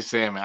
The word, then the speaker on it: salmon: I say salmon. I don't pronounce the L.